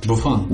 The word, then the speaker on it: Buffon: Buffon, how do you say it? – Buffon. – Yes.